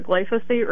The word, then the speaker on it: glyphosate: We know about Roundup, the glyphosate.